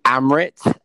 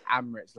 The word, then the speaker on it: Amrit: Yeah, so it's actually Amrit. My mum just butchered the name. So she felt that she was playing a bit of Scrabble. She chucked a bit of A's in there as much as possible. But it's Amrit, like the airlines with an A.